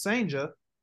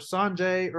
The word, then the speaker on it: Cijntje: Now, I was dead on with Cijntje, and I even informed a couple people, you know, within the media, hey, it's Cijntje, and they still want to say Cijntje or Sanjay or – look, guys, this kid is too good to mispronounce his name.